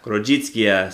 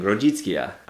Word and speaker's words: Grodziskie: I did notice that there is, like, a preferred German word pronounced Grätzer. Grätzer. So that's a little bit easier to look and see. It's as opposed to Grodzicka.